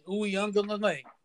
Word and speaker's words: Uiagalelei: I looked it up, and I heard some people say – I actually asked a Clemson fan – how to pronounce it, and that's what he said. Uiagalelei.